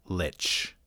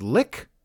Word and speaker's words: lich: A lot of people on the old internet say, are you sure it's not pronounced lick or leech? According to Frank Mencer's Dragon article, you can pronounce it lich or lick.